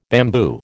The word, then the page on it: bamboo: American English